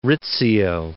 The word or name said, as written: Rizzio